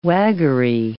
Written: waggery

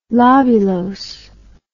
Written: lobulose

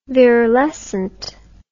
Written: virilescent

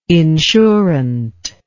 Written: insurant

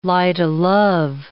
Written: light-o'-love

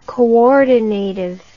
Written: coordinative

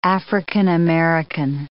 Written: African-American

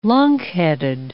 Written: lunkheaded

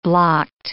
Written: blocked

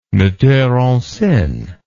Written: metteur en scene

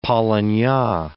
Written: polynya

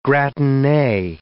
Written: gratinee

